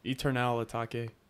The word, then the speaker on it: Eternal Atake: Atake. Ew. That's how you pronounce it. Why do you have to do that? – Eternal Atake.